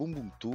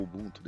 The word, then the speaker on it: Ubuntu: Sabe, Ubuntu ou Ubuntu, depende de quem pronuncia, enfim.